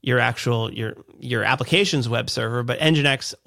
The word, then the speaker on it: Nginx: You didn't have to manage your Nginx server or Nginx. Is Nginx a server? Is that a correct way to say that? – Yeah. Nginx is a web server that will handle incoming requests and do SSL certificates.